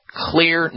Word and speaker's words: Xlear: It's spelled X-L-E-A-R. The X is for xylitol, because it has a lot of xylitol in it, which is good. And so what you're basically doing is clearing your nose with clear nasal spray, spelled with an X, X-L-E-A-R.